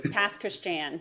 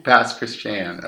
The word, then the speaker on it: Pass Christian: But how do you say it again? – Pass Christian. – Pass Christian, okay. – Yeah, or you can just call it the Pass.